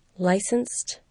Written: licensed

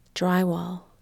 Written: drywall

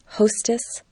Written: hostess

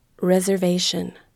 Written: reservation

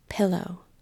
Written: pillow

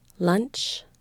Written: lunch

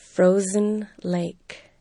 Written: frozen lake

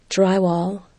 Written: drywall

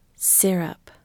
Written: syrup

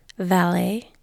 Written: valet

